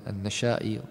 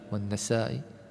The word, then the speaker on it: النسائي: أيضا قال: النشائي والنسائي، نفس الصورة، صورة الخط، لكن الاختلاف في النطق.